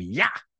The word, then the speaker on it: Iah: The moon in early Egyptian language is spelled I-A-H, which I assume has the charming pronunciation of Yah, would be the moon.